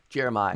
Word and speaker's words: Jeremih: Jeremiah. – I'm not sure. Is it Jeremiah? Okay. – Jeremiah. No way. It's spelled Jeremih. So, you know, who knows how he actually pronounces it.